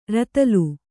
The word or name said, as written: ರತಲು